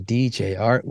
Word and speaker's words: DJ Art: And my name is Jared, aka DJ Art, with two T's for a double dose. of That tink tink. The D is silent, so it's just Jart.